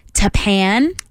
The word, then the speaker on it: Tappan: And we also have Richard in Tappan.